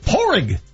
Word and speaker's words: Padraig: Porig Smith is how we pronounce his name right here on Altitude 950.